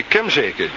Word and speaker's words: Kemzeke: En die woont aan de Stroperstraat nummer 4 in Kemzelen. Nee, Kemzeken. Kemzeken.